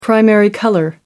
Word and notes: primary colour: American English

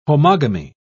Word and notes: homogamy: British English

